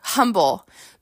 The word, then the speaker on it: Humble: There was a town called Humble there. Humble. But we called it Humble because we like in Houston, people drop the H's if it's at the beginning of the word, right?